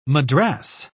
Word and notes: madras: British English

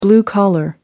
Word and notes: blue collar: American English

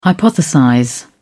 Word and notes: hypothesize: British English

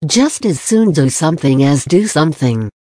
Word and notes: just as soon do sth as do sth: American English

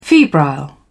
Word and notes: febrile: British English

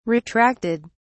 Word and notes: retracted: British English

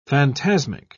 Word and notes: phantasmic: British English

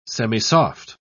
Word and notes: semisoft: British English

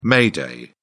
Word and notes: may day: British English

